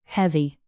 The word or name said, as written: heavy